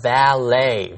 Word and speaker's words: valet: The valet, V-A-L-E-T. – See, all these words originated in French, and their E-T ending is pronounced A. The T is silent. – Got it. B-A-L-L-E-T, 这个字的发音很特别，读作 ballet，它的重音在第二音节，而且结尾的 T 也不发音。它是外来词，从法文来的，所以它的发音很特殊。还有其他几个例子， B-U-F-F-E-T 读作 buffet， buffet 是自助餐， F-I-L-L-E-T 读作 fillet， fillet 是西餐的鱼片， B-O-U-Q-U-E-T 读作 bouquet， bouquet 是一束花。还有 V-A-L-E-T， valet 是代客停车的服务生。所有的这些字原本都是法文，因此 ET 都读作 A 的音。